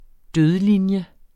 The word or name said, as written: dødlinje